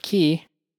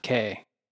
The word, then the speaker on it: Cay: And I guess while we're on the subject of Cay, that is the correct pronunciation. It's Cay, not K.